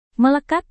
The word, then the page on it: melekat: Indonesian